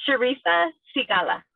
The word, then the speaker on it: Charisa Sigala: Am I saying your name correctly? – Almost. – Okay. – It's Charisa Sigala.